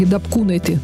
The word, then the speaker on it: Дапкунайте: Круглая дата 20 января у актрисы Ингеборги Дапкунайте. Меня учили, что ударение должно быть именно так, в литовском имени.